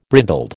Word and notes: brindled: American English

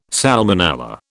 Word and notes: salmonella: British English

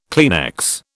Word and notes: Kleenex: British English

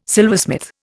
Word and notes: silversmith: Indian

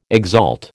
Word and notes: exalt: American English

